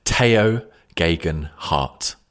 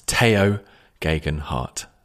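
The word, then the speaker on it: Tao Geoghegan Hart: well, I'm just going to interrupt the podcast to tell you that the correct pronunciation we have discovered is Teo Geoghegan Hart. That's Teo Geoghegan Hart.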